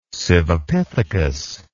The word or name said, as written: Sivapithecus